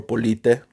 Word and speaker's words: Polite: This is your host, Papi Polite, uh, or Polite is how you really pronounce my last name. But for marketing purposes, let's just go with Polite.